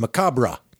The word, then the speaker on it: macabre: But then I listened to this, and they were very clear that it is Macabre. Like Macabre de Cadabra.